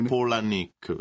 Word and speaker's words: Palahniuk: In effetti si dovrebbe dire Palahniuk, perché erano i nomi dei nonni, Pola, esatto. E Nick. Molti, esatto, dicono Palahniuk, però...